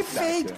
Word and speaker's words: Feid: ¿Por qué Fate? Cuéntanos. La explicación es bien especial porque Fate es la pronunciación de una palabra en inglés que a mí me gusta mucho, que, que es Fate, que significa fe.